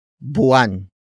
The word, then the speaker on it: buwan: It is an object marker that introduces what I am drawing for Isaac, and that is buwan. Buwan. Buwan. Buwan is spelled as B-U-W-A-N.